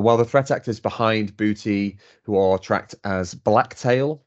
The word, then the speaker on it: Buhti: I'm going to pronounce this Buhti. So it's Bravo Uniform Hotel Tango India, Buhti ransomware. I think that's the way you pronounce it.